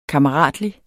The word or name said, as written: KAMMERATLIG